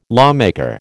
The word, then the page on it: lawmaker: American English